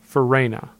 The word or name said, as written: Farina